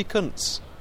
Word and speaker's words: Kuntz: And then my penultimate choice is Rusty Kuntz. That is how you pronounce it.